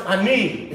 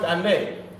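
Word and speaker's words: Ani (אני): It's pronounced Ani, it's not pronounced Ani. And it's like English, you get comfortable in saying a word and you're actually slanging it. So the proper way to say, I is Ani.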